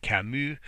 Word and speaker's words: Camus: Just like you don't pronounce the S in Camus, the famous French author, you don't pronounce that either.